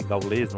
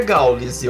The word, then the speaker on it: Gaules: Eu não consumo muito as streams, eu não costumo assistir, mas o, o Gaules aí, não sei gaulês, não sei o nome certo, me é perdoe, Gaules, me porque perdoe. Não, não tem acento circunflexo, é Gaules, eu que falei errado.